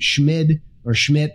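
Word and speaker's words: Schmid: The new Kansas City Fed president, Schmidt, I'm not sure how you say it. He's brand new. There's no T on the end.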